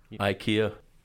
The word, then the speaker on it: IKEA: Do we say IKEA or IKEA? How would you say it, being of Danish extract? – IKEA.